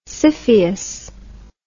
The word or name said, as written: scyphius